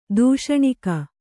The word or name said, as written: ತೂಷ್ಣೀಕ